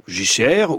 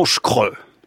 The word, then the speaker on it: JCR: Prononcé JCR ? – JCR, ou alors affectueusement Schkre.